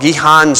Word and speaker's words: Gihon: Okay, and so in Bethlehem is a place called Gihon Springs. That's how we Westerners would pronounce that. In order to pronounce it the Israeli way, you got to have a head cold.